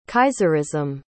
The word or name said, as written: kaiserism